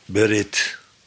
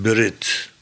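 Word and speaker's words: Berit (ברית): Het woord voor verbond wordt wel degelijk zo uitgesproken. Zoiets, Berit. Liefst met een wat stomme ede tussen, Berit.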